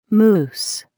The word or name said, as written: moose